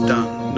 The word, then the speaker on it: Dunkirk: Dunkirk was a French Flemish or Dutch name, so often pronounced Dun, not Dune.